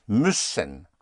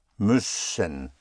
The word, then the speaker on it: müssen: In German, to have to, the verb to have to is müssen. M-U umlaut, for the two dots over the U, which gives you the Ü sound, where you have to whistle the E. Müssen, müssen.